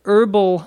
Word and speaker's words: herbal: My coworker, Ray, said that he was taking herbal supplements that are all natural. Herbal, H-E-R-B-A-L. Notice we don't normally pronounce the H. It is possible to say herbal, but most people say herbal. The word herbal comes from herbs, E-R-B, or E-R-B-S, plural, which are plants.